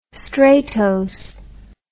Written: stratose

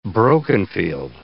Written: broken-field

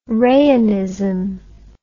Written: rayonism

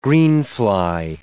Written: greenfly

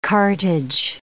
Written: cartage

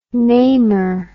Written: namer

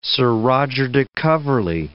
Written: Sir Roger de Coverley

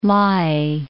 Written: Lae

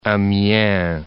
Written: Amiens

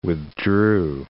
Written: withdrew

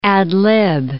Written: ad-lib

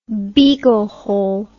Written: beaglehole

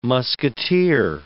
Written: musketeer

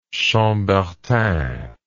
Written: chambertin

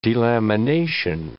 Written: delamination

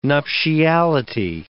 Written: nuptiality

